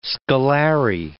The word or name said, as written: scalare